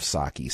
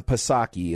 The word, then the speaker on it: Psaki: We will also be talking about something I am tremendously thankful for, and that is that Jen Psaki, and yes, I know the P is silent, but I just love Psaki, so I can't do it.